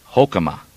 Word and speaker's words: Hoekema: That's spelled H-O-E-K-E-M-A. Hokema.